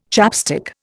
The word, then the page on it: chapstick: Indian